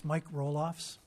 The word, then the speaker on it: Roloffs: It's Mike Roloffs. – Roloffs. – Right. Kind of like Jason Roloff, but not the same.